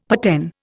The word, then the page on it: paten: Indian